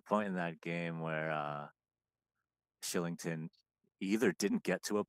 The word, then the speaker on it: Kylington: And the Bruins played the Flames weeks ago, and I want to say I forget, and Shillington, who until that game I didn't know that's how you pronounce his name. – I didn't know either. – Thought it was Kylington, so I apologize to him.